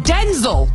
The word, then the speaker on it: Denzel: He says it's pronounced Denzel, but even he calls himself Denzel.